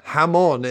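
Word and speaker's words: jamón: I feel like I'm not pronouncing that right, but hamon is like Spanish. Hamon is like Spanish for it.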